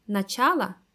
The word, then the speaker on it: начало: Например, в существительном «начало» ударение на втором слоге.